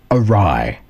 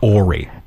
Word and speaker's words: awry: Awry. – Yes, and it looks like Ori. – And that's how I pronounced it in my head for the longest time, because I'd only ever read it.